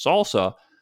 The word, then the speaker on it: SLSA: There's a new industry collaboration called the Supply Chain Levels for Software Artifacts, or SLSA, pronounced SALSA, whose URL is slsa.dev.